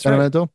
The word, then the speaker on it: Benevento: He's a drummer and he plays, he had a Marco Benevento, and I'm saying his name wrong. I can never. – No, you got it. That's Benevento.